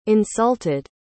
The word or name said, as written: insulted